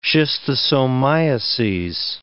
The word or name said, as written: schistosomiases